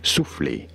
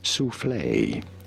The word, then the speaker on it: soufflé: Soufflé, for reference in French, it is said as souffle. Souffle. – Souffle. – In English, however, souffle is okay.